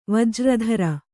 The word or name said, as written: ವಜ್ರಧರ